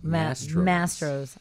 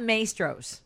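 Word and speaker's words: Mastro's: Mastros. – I don't know how I can write that. I actually can't write it phonetically because I know it's not Maestros the way a Chicagoan might want to say.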